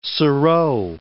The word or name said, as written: serow